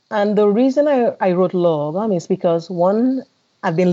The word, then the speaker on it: Lagom: Lagom, The Swedish Secret of Living Well. And Due North. What does Lagom mean? – So it's pronounced Lagom, and it is a Swedish kind of philosophy or ethos of living.